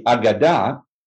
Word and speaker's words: Aagada: Let's talk about the Agada? Did I pronounce that? – Yep, sure. The Agada, A-A-G-A-D-A.